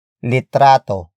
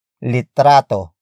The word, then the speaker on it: litrato: Word for word, it's para sa pagkuha ng litrato. – para, sa, pagkuha, ng, litrato, para, sa, pagkuha, ng, litrato.